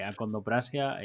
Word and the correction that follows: acondroplasia: eh, siempre lo digo mal. – acondroplasia, ¿no?